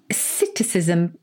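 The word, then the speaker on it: psittacism: Citticism, but it's got a silent P. – Oh, the P is silent, as in swimming pool.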